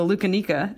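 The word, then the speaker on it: loukaniko: I was going to give you a shout out on your Greek heritage because I know the Lucanica. – Yeah. – Did I say that right? Correctly? – Yeah, exactly. Yeah, Lucanica. Yeah.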